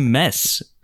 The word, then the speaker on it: Mads: I've been told by a Danish listener to the podcast we've been pronouncing Mads Mikkelsen's name. – You've gone full Lethal Weapon 2 there. – Yes. – Mids. – So apparently it's close to mess.